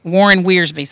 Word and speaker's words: Warren Wiersbe: Warren Wiersbe.